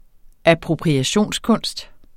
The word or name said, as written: appropriationskunst